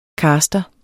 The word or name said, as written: caster